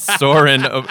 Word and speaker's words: Soarin': Um, number two, you secretly revealed that you love Disney when you correctly pronounced the ride Soarin' without the G at the end because it's Soarin' because it's lovably Soarin apostrophe.